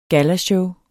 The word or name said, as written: gallashow